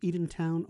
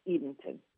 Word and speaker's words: Edenton: The town itself, is it pronounced Edentown or Edenton? – It's Edenton.